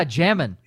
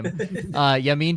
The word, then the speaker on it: Jamin: Oh, yeah, Jamin Davis. Not how you say his name. Yamin Davis from Kentucky.